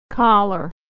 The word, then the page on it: collar: American English; /ər/